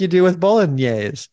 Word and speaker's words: bolognese: Of course, in English or American, you normally say it bolognese. Bolognese, but the typical Italian pronunciation is quite different. In fact, in Italian, they say bolognese.